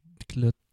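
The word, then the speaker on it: axolotl: Axolot, oui, ça s'écrit avec un L à la fin, mais je crois que le L est muet.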